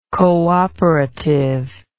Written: cooperativeness

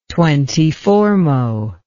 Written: twentyfourmo

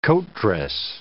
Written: coatdress

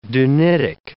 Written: dunitic

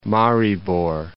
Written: Maribor

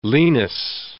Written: lenis

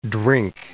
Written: drink

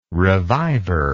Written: revivor

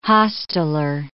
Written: hosteller

